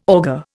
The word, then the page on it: augur: Indian